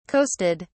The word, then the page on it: coasted: British English